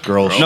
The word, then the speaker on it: Grolsch: How do you like pronounce that? Grolsch? – Grolsch.